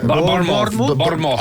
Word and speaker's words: Bournemouth: Bournemouth. – Ale to nemyslí z vás. – Bournemouth.